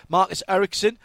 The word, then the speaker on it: Ericsson: Marcus Ericsson for Husky Chocolate Chip Ganassi Racing.